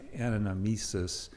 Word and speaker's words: anamnesis: And that word in liturgy is, I always never pronounce it quite right, but it's anamnesis.